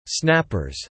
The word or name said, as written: snappers